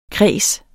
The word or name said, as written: kreds